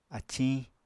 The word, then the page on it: aciñ: Apatani